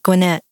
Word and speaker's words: Gwinnett: Not Gwinnett, but Gwinnett. – Gwinnett. – That's how we pronounce it here.